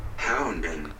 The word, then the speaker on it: Houngan: Houngan.